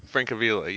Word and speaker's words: Francavilla: Fresca, Frescaella. – Oh, yeah. – You know what I'm talking about? – Francavilla? – Francavilla, yes, there you go.